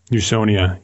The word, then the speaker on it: Usonia: and I don't know if I'm pronouncing it correctly, but Usonia? – Usonia, yes.